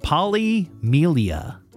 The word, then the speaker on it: polymelia: The condition that uh, is affecting Elsie is called polymelia. Polymelia, and I even Googled how to pronounce that correctly and it occurs roughly once in every 100,000 births.